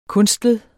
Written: kunstlet

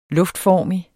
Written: luftformig

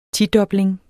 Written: tidobling

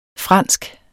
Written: fransk

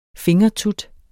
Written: fingertut

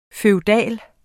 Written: FEUDALE

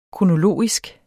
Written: kronologisk

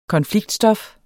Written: konfliktstof

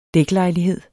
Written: dæklejlighed

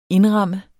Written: INDRAMMEDE

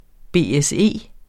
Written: BSE

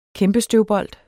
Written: kæmpestøvbold

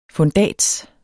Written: FUNDATSERS

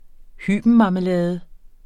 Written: hybenmarmelade

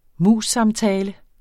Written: MUS-samtale

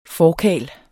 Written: forkarl

